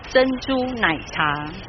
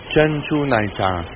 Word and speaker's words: 珍珠奶茶: 珍珠奶茶。 – 珍珠奶茶。